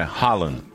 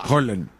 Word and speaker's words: Haaland: E não é Haaland. – A pronúncia? – Rola. Roland. Nós escandinavos. – Certo, você é de lá. – Nós escandinavos. É o seguinte, toda vez que você vê dois As juntos, você fala O.